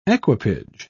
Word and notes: equipage: British English

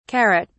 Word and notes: carrot(s): British English

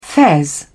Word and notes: fez: British English

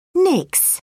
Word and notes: nix: British English

